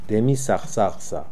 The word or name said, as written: Demisakhsakhsa